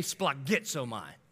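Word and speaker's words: splagitsomai (σπλαγχνίζομαι): That word compassion in the Greek is splagitsomai. Say splagitsomai. All right, if you're not like puking up some breakfast, you're not saying it right, all right?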